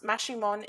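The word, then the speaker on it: Maximón: Saint Simon Maximon, pronounced Maximon in several Mayan dialects, also known as El Hermano, is an ancient Mayan deity and now considered a folk saint.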